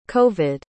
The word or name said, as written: covid